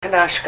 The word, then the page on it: knashka: Ninilchik Russian